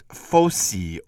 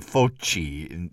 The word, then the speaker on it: fossi: Dante's term for these consecrated stairs, these narrow restrictions, is fossi, or if you pronounce it in modern Italian, foci. It's probably or likely that Dante said fossi and not foci, but no matter which one.